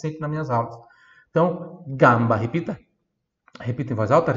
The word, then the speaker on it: gamba: A única diferença aqui da pronúncia desse GA para a língua portuguesa é que o A no italiano é bem mais aberto, então eu falo gamba, gamba, né?